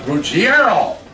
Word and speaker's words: Ruggiero: I said, it's R-U-G-G-I-E-R-O. He said, you even have an Italian background. You know that's not the way you pronounce it. It's Ruggiero. I said, well, Bob, you know that, and I know that, but Reggie and his family think it's Ruggiero.